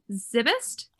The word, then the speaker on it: Szybist: Her last name is spelled Szybist. So I think it's Zybist.